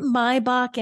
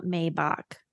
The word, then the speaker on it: Maybach: So, why is it Maybach and not Maybach? – That's the German pronunciation of it.